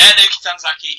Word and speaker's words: Naoki Tanizaki: He's facing former Dragon Gate star, I hope I pronounce this right, Naoki Tanizaki. – That's it. Yeah, Naoki Tanizaki, he's a former Open the Grave Gate champion for Dragon Gate, which is the secondary title behind the Dream Gate.